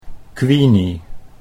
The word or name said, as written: Queenie